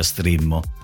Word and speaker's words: Streammo: Si scrive Streammo ma si pronuncia Strimmo.